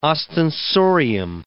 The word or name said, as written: ostensorium